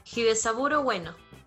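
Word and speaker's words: Hidesaburō Ueno: Eh, te lo digo, Hidesaburo Bueno.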